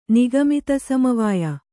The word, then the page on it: ನಿಗಮಿತಸಮವಾಯ: kannada